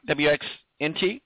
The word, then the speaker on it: WXNT: You're now over at CBS with your new gig, local CBS 1430 WX INT. Did I say that right? WXNT. I'm sorry. – XNT. That's it.